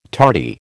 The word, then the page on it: tardy: American English